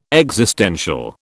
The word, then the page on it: existential: British English